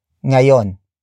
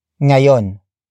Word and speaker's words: ngayon: Kumain is spelled as K-U-M-A-I-N. It means ate, or the act of eating has happened. Finally, we have ngayon. Ngayon. Ngayon. Ngayon is spelled as ng. A. Y. O. N. It means now or today.